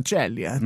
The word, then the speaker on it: Ceglie: Da ist er aufgewachsen, der 26-jährige Chase Chelier man schreibt das C-E-G-L-I. E. – Hast du Italiener? – Anscheinend schreibt man es italienisch aus. – Aha. Celie. Celie. Ja, Celie. Mhm.